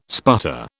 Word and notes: sputter: British English

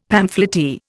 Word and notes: pamphleteer: Indian

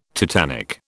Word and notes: tetanic: British English

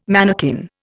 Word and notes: manakin: Indian

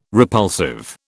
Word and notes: repulsive: British English

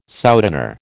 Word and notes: souteneur: American English

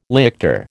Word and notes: lictor: American English

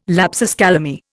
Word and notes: lapsus calami: Indian

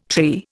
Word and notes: tree: Indian

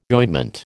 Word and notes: ointment: American English

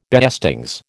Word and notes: beestings: American English

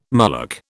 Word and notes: mullock: British English

